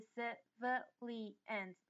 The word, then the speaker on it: civilians: 所以 researchers and civilians 这个字也超难念的，大家注意一下 civilians 这边呢是有四个音节，分别是 civilians 要特别注意那个 vi 是重音的部分，因此发音的时候就 civilians 这样子发的话会比较清楚。